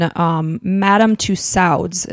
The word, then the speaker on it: Madame Tussauds: And I guess right before his execution, Madame Tussauds, is that how it was pronounced? – Yep.